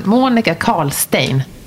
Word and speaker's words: Monica Karlstein: Välkommen till inredningspodden Monica Karlstein. Tack! Det var rätt uttalat.